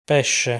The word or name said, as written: Pesce